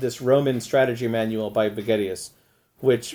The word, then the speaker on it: Vegetius: And Vegetius has, which I think actually is the better pronunciation, has divides his book into four chapters, and the last two, which are the let me try to explain this more clearly.